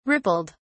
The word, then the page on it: rippled: British English